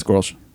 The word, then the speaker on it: Grolsch: In the name of Joel, we're drinking Grolsch. – Oh, fuck yeah. – If I said that right. – Yeah, it's Grolsch.